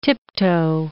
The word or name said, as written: tiptoe